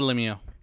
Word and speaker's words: Lemieux: I don't even know if I'm pronouncing that properly. It's like a French word. – It is the Lemieux. – It is the Lemieux? – Yeah, because we have one here.